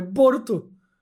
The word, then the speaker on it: Boruto: E aí é só o jeito que japonês pronuncia Bolt. É Boruto.